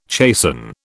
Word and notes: chasten: British English

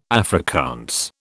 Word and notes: Afrikaans: British English

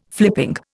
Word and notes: flipping: Indian

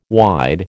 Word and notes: wide: American English

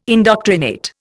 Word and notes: indoctrinate: Indian

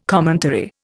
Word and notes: commentary: Indian

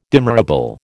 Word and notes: demurrable: American English

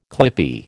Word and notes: clippie: American English

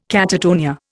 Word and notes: catatonia: Indian

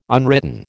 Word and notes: unwritten: American English

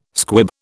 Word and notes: squib: British English

